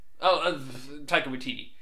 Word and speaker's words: Taika Waititi: Taika Waititi. I probably pronounced his name wrong, but... – No, that's right.